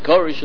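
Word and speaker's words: Koresh (כורש): Cyrus is very far from the pronunciation. Koresh is very close to the pronunciation, but, you know, not quite the way the Persians would call him.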